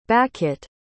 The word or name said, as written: bakhit